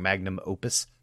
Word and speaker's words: magnum opus: magnus opus for them. – Mm. – And I said that wrong. Magnum opus. For them, not a magnus opum.